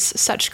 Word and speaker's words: Sutch: My website is Such Creative Work, but Such is spelled with a T. So S-U-T-C-H. That is my last name. The pun is intended.